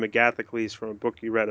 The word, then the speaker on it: Agathocles: It's Well, in Greek, it's called Agathocles. Agathocles. Well, that's the ancient Greek pronunciation.